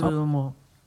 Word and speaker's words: Holodomor: Und da wäre einmal der Holodomor, wie spricht man das? – Holodomor. Betonung auf der ersten Silbe.